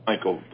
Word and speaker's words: Michele: My name is Michael, by the way. – I beg your pardon? – My name is spelled the Italian spelling of it's M-I-C-H-E-L-E, which is really Michele, but in English would be Michael.